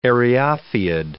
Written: eriophyid

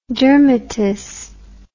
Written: dermatous

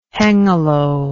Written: hengelo

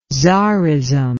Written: tsarism

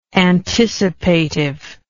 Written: anticipatively

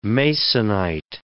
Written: Masonite

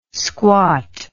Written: squat

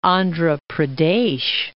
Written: Andhra Pradesh

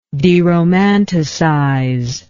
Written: deromanticize

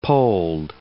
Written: polled

